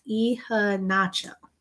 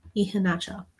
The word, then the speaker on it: Iheanacho: Oh, his name is pronounced Iheanacho. Iheanacho.